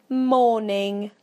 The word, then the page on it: morning: British English